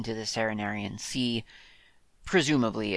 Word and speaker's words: Cerenerian: The Serenarian Sea is spelt C-E-R-E-N-E-R-I-A-N, Serenarian Sea.